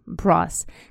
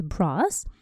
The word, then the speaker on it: brass: Brass. Как раз посмотрим с вами на этот металл. Это латунь на самом деле. В Великобритании это слово произносится brass, а в Америке brass.